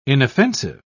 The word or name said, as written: inoffensive